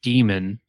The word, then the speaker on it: Daemon: It's called Demon. It's spelled Daemon.